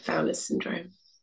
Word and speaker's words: Fowler's syndrome: Fowler's syndrome.